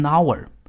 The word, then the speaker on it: hour: 比如一个小时，用英文表达是 an hour。虽然 hour 这个单词是以 h 开头的，但因为 h 是一个不发音的哑音字母，所以这个单词的第一个发声因素是 ow，是元音因素，所以我们要用 am。